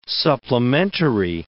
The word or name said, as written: supplementary